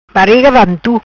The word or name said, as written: Bariga Vantu